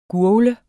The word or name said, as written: GURGL